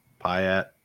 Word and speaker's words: Piatt: I'm not sure how to say his last name. It's Piatt. I'm not sure. P-I-A-T-T. – Piatt. – That's what I'm going with.